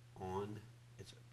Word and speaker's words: oni: The word for afflictions there is a word, it's the Hebrew word on, it's spelled like O-N space E-E, oni.